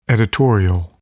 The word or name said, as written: editorial